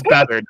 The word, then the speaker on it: Beathard: Yeah, it's Beathard. I know it's Beathard.